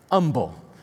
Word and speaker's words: Humble: Is that how you say it? Okay. Humble. That's hard for a country boy from Alabama. I want to say humble. You know, it's got an H in front of it. Let's pronounce it like it should.